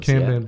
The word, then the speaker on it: Kanban: It's more like, if you know what Kanban boards are, and I'm pretty sure I'm pronouncing that wrong. Is it? No, I'm pronouncing it right. – Kanban boards.